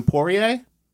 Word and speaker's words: Poirier: Fighter, how do you say this? Shannon Poirier? – Mm-hmm. – Yes.